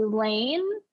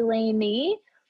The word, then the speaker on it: Laine: My name is spelled like it sounds like it would be Lane, but it's actually Laney. L I L A I N E.